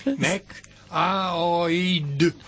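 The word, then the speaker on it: Mac Aoidh: Kiel oni pronuncas tiun nomon? – Mac Aoid. Mac Aoid.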